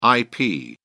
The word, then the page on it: ip: British English